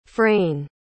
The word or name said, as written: frayn